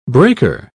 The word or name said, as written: breaker